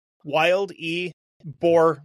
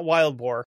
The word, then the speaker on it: Wildeboer: It's literally, it's spelled Wild E Boer, like the war. – Okay. – So I was like, it's not Wild Boar. I think it's Wildeboer. We'll go with Wildeboer because it sounds less cartoony.